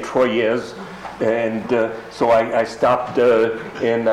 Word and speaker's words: Troyes: And he said, un, deux, trois. Trois, three. That's how you pronounce T-R-O-Y-E-S.